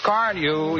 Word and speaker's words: Carnu: More car owners buy Johnson's Car New, you know, than any other car polish. It's spelled C-A-R-N-U.